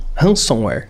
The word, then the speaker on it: ransomware: Ransomware.